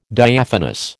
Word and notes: Diaphanous: American English

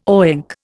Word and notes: oink: Indian

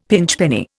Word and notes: pinchpenny: Indian

